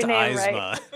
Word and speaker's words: Eisma: if anyone again follows me on Twitter, you may have noticed that artist Joe Isma. Eyes, it's pronounced Eisma. Right.